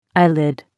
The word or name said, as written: eyelid